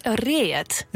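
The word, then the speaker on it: Read: Fries is een beetje roestig, maar hoe zou jij het uitspreken? – Reet. Ja, een beetje zo zangerig.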